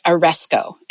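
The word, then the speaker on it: aresko (ἀρέσκω): The Greek word, which is what the New Testament was written in, the Greek word for please is the word aresko, and it's spelled A-R-E-S-K-O, aresko.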